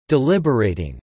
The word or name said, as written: deliberating